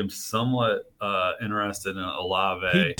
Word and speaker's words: Olave: How do you say his name? – Olave.